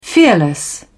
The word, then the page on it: fearless: British English